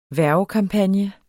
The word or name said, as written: HVERVEKAMPAGNE